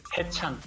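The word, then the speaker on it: Haechan: De NCT se llama, hay un chico que se llama Hechan. – Ajá. – ¿Lo dije bien? – También se pronuncia Hechan, como dices. Hechan.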